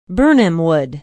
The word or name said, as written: birnamwood